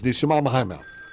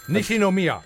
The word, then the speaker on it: Nishinomiya: I believe it's Nishimamahama. – Nishinomiya.